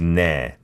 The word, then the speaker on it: 네: Yeah, and in the last line, I noticed 네, the first syllable 네 sounded a bit like 태. – Okay. – Yeah, but except for that, everything was perfect. – 네, not 태.